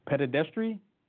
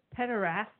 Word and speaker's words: pederasty: And let me see if I can pronounce this word correctly. Pedestry? – Pederasty.